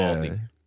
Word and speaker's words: Uvalde: Uvalde.